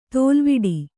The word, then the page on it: ತಲೆವೊಡೆ: talevoḍe